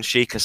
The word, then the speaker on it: Sheikah: The title is, you can now buy your very own Sheikah. Is that how you pronounce it? – Yep, Sheikah.